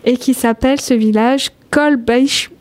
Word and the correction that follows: Kolbsheim: Ah non, voilà, j'ai raté mon coup. Je ne vais pas le refaire. Hein. – Kolbsheim. – Kolbsheim, qui s'écrit K-O-L-B-S-H-E-I-M, qui se trouve en Alsace, et qu'on va inviter nos amis auditeurs à aller aussi découvrir.